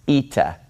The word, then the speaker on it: eta: This letter is pronounced eta. So we have zeta, and then we have eta. Zeta says z, and eta makes an e sound. This is a vowel that makes an e sound, eta.